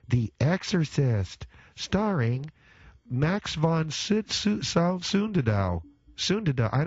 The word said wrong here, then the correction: Sydow: Starring Max von Sydow.